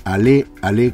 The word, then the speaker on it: Ale Ale: Pero si además ingresan en Facebook, busquen Ale Ale Cooperativa, así como suena, y por supuesto con acento en la E de Ale Ale.